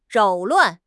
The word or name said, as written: 扰乱